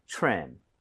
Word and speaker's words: trim: 그래서 다듬음, 다듬다라는 거둘다될 수가 있는데 이거 트림이 아니죠. Right? TRE 붙어 있어요. TRE 붙어 있고 그다음에 모음으로 연결이 되면 크리스마스 트리처럼 치읓 비슷해집니다. – 그쵸. – 그래서 입 모양을 모으고 발음해 주세요. 가보겠습니다. Trim, trim.